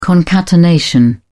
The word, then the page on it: concatenation: British English